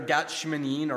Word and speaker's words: Gat Shmanim: And there, while in Israel, I learned from our Jewish tour guide that Gethsemane is actually a very bad pronunciation, or mispronunciation, I should say, of two Hebrew words, which are Gat and Shmanim. Gat meaning olive and Shmanim meaning press. So put together Gat Shmanim, or we took Gethsemane out of that somehow.